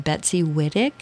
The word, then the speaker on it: Wittick: And then you've talked also to younger people that are making their way, people like Betsy Wittick. Did I pronounce her name right? – Yes.